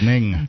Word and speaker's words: Ng: 15 years later, Hugh Lee Ning, his last name is spelled N-G. – Ng? Ning. – It's Ning.